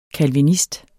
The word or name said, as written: CALVINIST